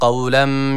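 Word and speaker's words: Kavlem (قولا): Kavlen kelimesine dikkat çekecektik. – Selamun kavlen. – Yani kavlen şeklinde okunuyor. – Selamun kavlem. Kavlem kaf. Yani kef harfi burada yok.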